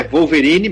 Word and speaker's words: Wolverine: se pronuncia com W ou com U, ou com V, ou como V, né, lembrando que o W em alemão tem som de V, então é Wolverine mesmo,